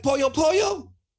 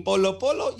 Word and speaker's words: Polo Polo: And then he goes, "Oh, like pollo pollo? You mean polo polo?